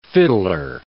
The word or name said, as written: fiddler